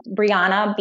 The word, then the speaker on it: Brianna: My name is spelled Brianna, B-R-I-A-N-N-A, and then Sylver, S-Y-L-V-E-R.